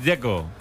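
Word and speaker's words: Džeko: Porque de Z, ¿cómo se pronuncia? – Dzeko. Bruno dice que es Dzeko. – Como tirando a J, Dzeko o algo así.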